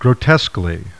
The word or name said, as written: grot